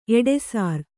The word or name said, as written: ಎಡೆಸಾರ್